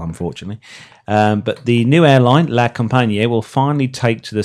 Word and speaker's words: La Compagnie: How would you say that, Simon? La Compagnie? La Compagnie. La Compagnie.